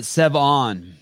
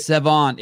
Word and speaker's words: Sevan: How you say your name? Like Seven? Like Lucky Seven? – Like Lucky Seven, but Sevan. – Sevan, I like it. – Sevan, it's a lake in Armenia.